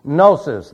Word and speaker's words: gnosis: One is gnosis. The G is silent in English. It is not in Greek. Gnosis is how we say it in Greek.